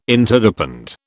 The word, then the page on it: Interdepend: British English